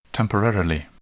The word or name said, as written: temporarily